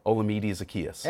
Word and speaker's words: Olamide Zaccheaus: Pronounced that correct, by the way. – Olamide Zaccheaus. – Yeah, pronounced it right.